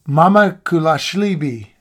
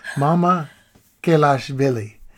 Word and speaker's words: Mamukelashvili: Number one on the bottom two was Sandro Mamukelashvili. Mamukelashvili.